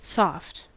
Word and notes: soft: American English

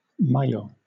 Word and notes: maillot: British English

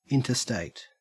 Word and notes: interstate: Australian English

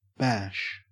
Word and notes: bash: Australian English

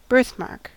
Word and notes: birthmark: American English

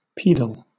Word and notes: pedal: British English

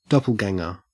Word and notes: doppelganger: Australian English

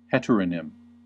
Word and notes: heteronym: American English

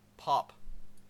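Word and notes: pop: Canadian English

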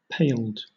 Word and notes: paled: British English

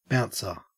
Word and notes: bouncer: Australian English

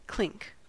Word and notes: clink: American English

